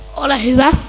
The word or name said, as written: ole